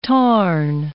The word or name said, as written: tarn